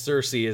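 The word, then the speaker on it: Sersi: I think that's how you say it. It's spelled weird, but yes, Cersei is the way you do it.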